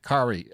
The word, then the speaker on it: Cary: His name is Cary, because I actually Googled it and it was like Kari, sounds rhymes with Atari.